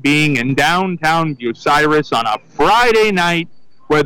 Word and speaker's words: Bucyrus: Well, it's actually not tomato, tomato, because there is a proper pronunciation for the town of Bucyrus, and it's called Bucyrus. Bucky Russ is not even close.